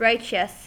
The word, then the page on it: righteous: American English